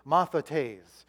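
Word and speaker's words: Mathetes (μαθητής): M-A-T-H-I-T-I-S. Is it up there? There you go. You got it. Mathetes is how you say it.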